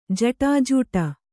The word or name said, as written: ಜಟಾಜೂಟ